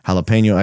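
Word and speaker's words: jalapeño: They pronounce it with the Spanish pronunciation, jalapeno. Actually,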